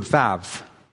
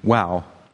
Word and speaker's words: Vav: And the 6th letter of the Hebrew alphabet is called Vav in modern pronunciation, though most scholars would agree that a more authentic biblical pronunciation would be WOW, hence the title of the sermon.